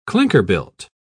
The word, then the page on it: clinker-built: British English